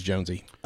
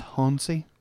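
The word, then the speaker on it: Jónsi: This is Jonesy. – Okay. – So it's pronounced Honsey. – It's not pronounced Honsey. – I think it is actually. – Is it? – Yeah, I think it might be.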